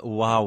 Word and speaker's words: waw: I don't know how to pronounce the Semitic letter, which is spelled W-A-W. – I think it's something like wow.